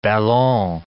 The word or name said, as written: ballon